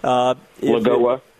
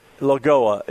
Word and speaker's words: Lagoa: When the hearings happen, if it happens to be Barrett, or if it's, was it Loja? Is that how she pronounces her last name? – Lagoa. – Lagoa.